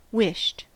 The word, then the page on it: wished: American English